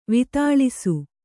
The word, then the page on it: ವಿತಾಳಿಸು: Kannada; vitāḷisu